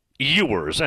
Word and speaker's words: Ewers: 614 Rob has a picture here of Quinn Ewers. And again, it's spelled E-W-E-R-S. Pronounced Ewers, best I know.